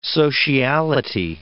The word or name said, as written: sociality